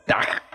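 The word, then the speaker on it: takk: Aquí el gran Naure me acaba de dar el, la traducción de cómo se dice gracias en noruego. Es tag, tag, a todos nuestros amigos de Noruega.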